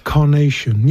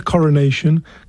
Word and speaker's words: carnation: Carnation. Yeah, coronation. Carnation.